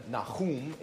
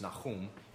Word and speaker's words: Nahum: Obadiah, Jonah, Micah, Nahum is how it's pronounced in Hebrew. Nahum is just an English way of saying it, but it's Nahum. Every time I say Nahum in the presence of an Israeli, they correct me.